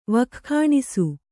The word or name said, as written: ವಕ್ಖಾಣಿಸು